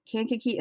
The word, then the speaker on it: Kankakee: He grew up in Kankakee, Illinois. I'm pretty sure that's how you pronounce that name. I listened to the audiobook. That's how the reader said it.